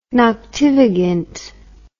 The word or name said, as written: noctivagant